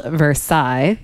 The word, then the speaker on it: Versailles: That's how it's pronounced, even though it's spelled Versailles. – Uh-huh. It's pronounced Versailles.